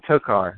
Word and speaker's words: tokar: And there's all these people that are showing up trying to get in Danny's Tokoro. Or no, Tokar. That's how you say it.